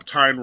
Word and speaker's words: Tyne: you transplant him to the United States and make him a sullen guy in L.A., might as well also drop Tyne rhymes with wine.